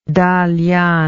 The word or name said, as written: Dalian